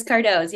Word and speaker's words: Cardoze: It is Cardoze, yep.